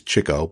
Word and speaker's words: Chico: I know the proper pronunciation is Chico, but I say Chico and always have and make no apologies for it.